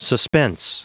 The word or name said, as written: suspense